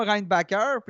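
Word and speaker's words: Reinbacher: Il a dit Appelez-moi Reinbacker. Puis là, bon, on lui a demandé Est-ce que c'est vraiment comme ça que ça se prononce Sa réponse ben, Vous n'êtes pas capable de prononcer Reinbacker comme, comme, il faut, donc euh, dites dites Reinbacker parce que oh, Ici, là, je, suis le seul.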